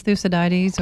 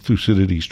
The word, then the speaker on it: Thucydides: Did they know how to pronounce Thucydides or? – No, Thucydides. – I knew I was going to get it wrong again. – No, no, no. Thucydides. Yes, indeed.